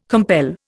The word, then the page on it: compel: Indian